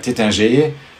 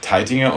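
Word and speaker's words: Taittinger: Viele Häuser haben deutsch klingende Namen, auch wenn die im Französischen ausgesprochen, wenn man das nicht so hört, Heidsieck oder Tettinger, aber Teitinger und Heidsieck sind ganz klar deutsche Namen.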